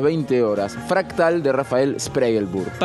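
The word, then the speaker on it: Spregelburd: Rafael Spregelburg. ¿Lo dije bien, Ellensweig? – Perfecto. – Spregelburg, con dirección y puesta en escena de Hernán Ledesma.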